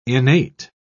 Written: innate

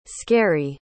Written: scarry

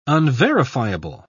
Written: unverifiable

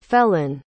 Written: felan

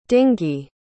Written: dingee

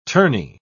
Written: tourney

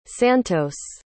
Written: santos

